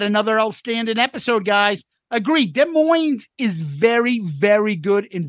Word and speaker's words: Des Moines: Love both listening to Soundboard at Des Moines, the S is silent in 77, and your coverage of it.